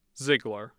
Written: zeigler

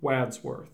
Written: Wadsworth

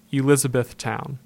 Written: Elizabethtown

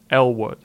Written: Elwood

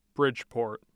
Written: bridgeport

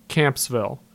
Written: Kampsville

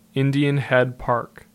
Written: Indian Head Park

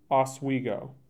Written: Oswego

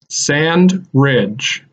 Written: Sand Ridge

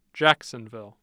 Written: Jacksonville

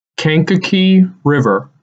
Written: Kankakee River

